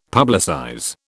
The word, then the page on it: publicize: British English